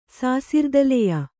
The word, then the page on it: ಸಾಸಿರ್ದಲೆಯ: Kannada